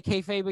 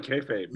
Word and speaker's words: kayfabe: How do you say kayfabe again? I know I said it wrong on one of the podcasts. You said it right, kayfabe.